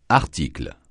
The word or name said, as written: article